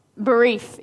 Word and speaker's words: Berith: Berith is how you pronounce that in the Hebrew.